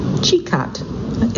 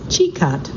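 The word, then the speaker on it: Čikat: The jewel of Losinge, and by the way, that's spelled L-O-S-I-N-J, is Sikat, Chikat. It's nice, he's giving me pronunciations as I go. So the jewel of Losinge is Chikat, C-I-K-A-T,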